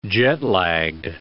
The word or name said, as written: jet-lagged